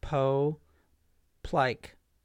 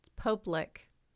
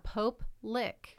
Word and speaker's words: Pope Lick: I think you're saying it wrong. – No, it's called the Pope Lick. – No, I think it's pronounced Poe Plike. – What? – Or Pope Lick. – Pope Lick. And we got a new pope, so... – Is it two separate words? – Yeah, Pope Lick.